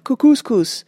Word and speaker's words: Kookooskoos: Anyway, welcome to Camp Kookooskoos. It rhymes with moose.